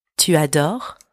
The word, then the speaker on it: tu adores: Tu adores. Tu adores. And don't pronounce the S at the end.